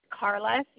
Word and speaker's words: Carla: It's Carla, C-A-R-L-A, last name Trine, T-R-I-N-E.